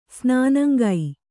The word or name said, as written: ಸ್ನಾನಂಗೈ